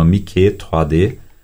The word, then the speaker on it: Mickey 3D: Mickey 3D. Se escreve, né, Mickey como Mickey Mouse, e 3D ou 3D, apenas pronunciado em francês.